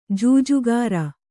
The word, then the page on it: ಜೂಜುಕೋರ: jūjukōra